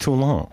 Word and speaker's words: Toulon: Pronounced Toulon.